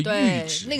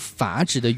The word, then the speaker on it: 阈值: 那个实际上就是门槛，就是阈值。 – 那个就是你的阈值。 – 但是经常会把这个读成阀值的原因，除了它长得像之外，它有点很多人会把那个东西理解成阀门。